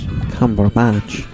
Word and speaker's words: Cumberbatch: I said, you kind of got a Beatrix Potter character name, Benedict Cumberpatch. And he goes, actually, it's Cumberbatch. – That's right. He's very gracious about it.